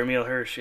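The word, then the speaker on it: Emile Hirsch: Is that how you say his name? Well, that's the actor, Emil Hirsch, yeah.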